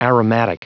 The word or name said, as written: aromatic